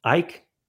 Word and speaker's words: Icke: Is that how you say his name? Ike? – Yes. – Icky.